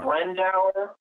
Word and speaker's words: Brandauer: Brandauer.